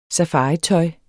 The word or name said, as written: safaritøj